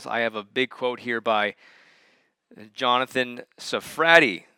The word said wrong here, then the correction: Sarfati: That's how you say it, I believe. Nope, it's Sarfati.